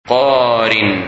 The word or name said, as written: Qarin